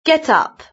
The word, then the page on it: get up: British English